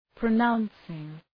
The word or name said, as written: pronouncing